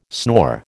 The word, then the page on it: snore: American English